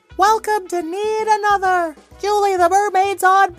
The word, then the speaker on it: Knead: Come on in to Need Another, but need is spelled K-N-E-A-D like kneading dough.